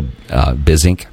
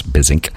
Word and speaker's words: Buissink: His name is Joe Bizink. And I think that's how you pronounce it. The last name is spelled B-U-I-S-S-I-N-K. So I think it's like business. It's Bizink. And Joe is pretty amazing.